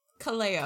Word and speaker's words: Kaleo: Well, how did you just pronounce that? – Kaleo? – Kaleo. – Kaleo? Is that how you say it? – Yeah. Yeah.